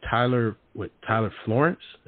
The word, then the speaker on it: Tyler Florence: How was it working with, I forgot his name, Tyler Florence. Am I saying his name right? – Yes. Yes, he's fantastic.